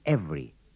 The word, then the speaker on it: every: In the sentences and dialogues, don't pronounce the second E in every.